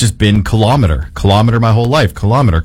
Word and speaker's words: kilometer: Second syllable stress, kilometer. This style is very common in everyday Canadian speech for some reason, influenced by measuring devices like thermometer and speedometer.